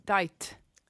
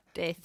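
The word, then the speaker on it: Daith: Men det stavas David Adam Ivar Tore Harald. – Dajt. – Precis. Och då säger vissa death. – Death. Vissa death. Säger death.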